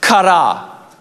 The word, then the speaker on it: chara: Actually, the word joy in the Greek is pronounced chara, C-H-A-R-A, and it can be defined as this.